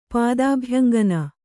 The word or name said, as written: ಪಾದಾಭ್ಯಂಗನ